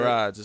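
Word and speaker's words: Gerads: I always forget how to pronounce Gerard's. – It. – Gerard's. It's a hard G.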